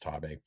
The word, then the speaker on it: Xtabay: If you've ever heard of Ema Sumac, a singer, her most famous album, Voice of the Tabe, which is spelled X-T-A-B-A-Y, but pronounced Tabe.